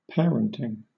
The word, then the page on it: parenting: British English